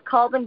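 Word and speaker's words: Colvin: It's Colwyn B. like Victor, but that's cool.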